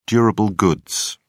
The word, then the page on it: durable goods: British English